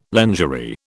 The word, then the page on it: lingerie: British English